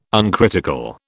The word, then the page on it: uncritical: British English